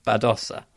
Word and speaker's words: Badosa: Hello, Spanish speakers pronunciation. – Badosa. – All right, I'm going to correct myself going forward then.